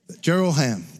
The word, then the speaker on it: Jeroham: I said that name wrong. It's not Jeroham. Jeroham, got it.